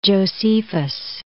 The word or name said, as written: Josephus